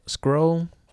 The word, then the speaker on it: SCRL: Another app I use is called Scroll. Well, it's spelled S-C-R-L.